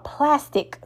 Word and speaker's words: Plastiq: Well, I found out about something called plastic today, and that's spelled P-L-A-S-T-I-Q.